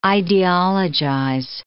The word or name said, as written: ideologize